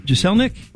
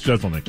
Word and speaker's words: Jeselnik: And is it pronounced Jeselnik? – Jeselnik. – Jeselnik. I always get his name wrong. – I think it's Jeselnik.